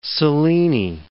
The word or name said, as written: Selene